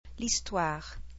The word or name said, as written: l'histoire